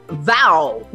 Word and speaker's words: VAAO: We are talking about VAO. Now I know how to pronounce it. V-A-A-O.